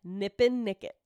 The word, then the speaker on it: Nippenicket: I've said it wrong every time I've said it. – Nipinicket? – Nipinicket. N-I-P-P-E-N-I-C-K-E-T. Nipinicket. – Nipinicket. – I want to say Nip and Nugget like Nantucket, and I'm like, that's not right.